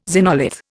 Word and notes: xenolith: Indian

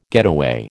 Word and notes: getaway: American English

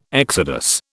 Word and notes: exodus: British English

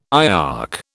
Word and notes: IOC: British English

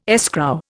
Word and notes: escrow: Indian

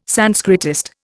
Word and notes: Sanskritist: Indian

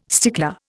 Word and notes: stickler: Indian

